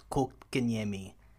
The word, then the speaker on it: Kotkaniemi: It is pronounced Kotkaniemi. This is the pronunciation. I mean, I've been to Finland. I've hung with Finnish people. This is how they pronounce it.